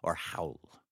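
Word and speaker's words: Raul: Yes, keep in mind, Devin, the R is silent in his name, or howl. – It's howl. An H. – Yeah, like an H. Howl.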